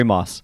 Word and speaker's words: Maass: Corey, did I say your last name right? I forgot to ask you before we started recording. – Yes, Corey Maas.